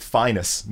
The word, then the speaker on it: Phineas: Their father pronounced it Finus Newborn.